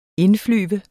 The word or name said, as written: INDFLYVER